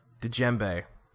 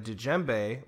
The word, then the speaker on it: djembe: A djembe or djembe. I think the D is silent. Djembe.